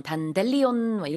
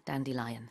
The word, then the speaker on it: Dandelion: 근데 가끔 요거 발음 잘못하는 경우 제가 본 적이 있는데 단델리온 이렇게 발음하는 거본 적 있거든요. – okay. – 영어로는 Dandelion입니다. Just like a lion, 사자 같은 그런 발음이에요. Dandelion.